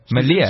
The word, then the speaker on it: Malia: So I've been following, I don't know if I pronounce her name right, but is it Malia? – Yeah, it's the daughter, yeah. – So I've been following her, and at the moment, she's really into series. – Malia.